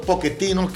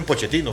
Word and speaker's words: Pochettino: Mauricio Poquetino, que así oí que se pronuncia, da positivo al COVID-19. Es que en Argentina le dicen Pochettino, pero allá en Europa le dicen Poquetino porque creo que se pronuncia en italiano.